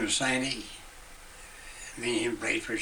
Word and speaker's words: Santy: Frank Sandy, S-A-N-T-Y. Frank Sandy.